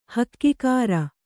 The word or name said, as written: ಹಗೆಗಾರ